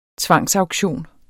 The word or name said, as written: tvangsauktion